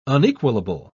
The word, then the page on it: unequalable: British English